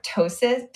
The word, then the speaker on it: ptosis: Yeah, and I forget they do it for ptosis. How do you say it? – Ptosis. The P is silent.